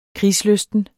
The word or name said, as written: krigslysten